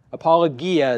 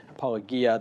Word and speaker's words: apologia: Apologia is actually how you say it. Apologia, that's the Greek word.